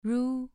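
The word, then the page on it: ru: tone 2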